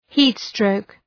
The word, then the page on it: heat stroke: ‘hi:t,strəʋk